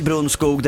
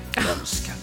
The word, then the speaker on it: Brunskog: Vi säger Brunskog, det du säger... – Brönske. – Brönske, så ska det vara.